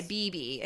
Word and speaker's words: Bebe: Is it BB Newworth? Yes. – Okay, BB, because it's spelled like the bebe, like how Moira pronounces baby.